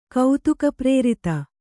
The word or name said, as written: ಕೌತುಕಪ್ರೇರಿತ